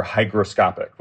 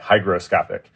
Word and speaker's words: hygroscopic: So the process that enables these hydro panels to work, it's called hydroscopy. Am I saying that right? – Yeah, so many, many materials are hygroscopic, with a G, hygroscopic, because Greek for water vapor was hygro with a G, and Greek for liquid water was hydro with a D.